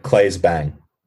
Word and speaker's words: Claes Bang: I mean, I've only seen him in one thing, but Claes Bang. – That's how you pronounce his name.